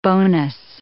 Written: bonus

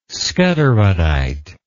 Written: skutterudite

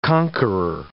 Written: conqueror

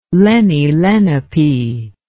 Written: lenni-lenape

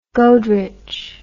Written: goderich

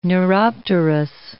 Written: neuropterous

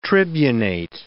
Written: tribunate

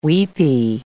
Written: weepy

